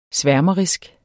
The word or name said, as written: sværmerisk